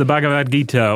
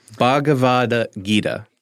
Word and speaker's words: Bhagavad Gita: Yes, the Bhagavad Gita, or, you know, you can just call it the Gita. – I always say it wrong. – Yeah. – Bhagavad Gita.